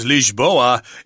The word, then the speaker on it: Lisboa: And it's pronounced Lisboa in Portuguese.